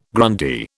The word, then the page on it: Grundy: British English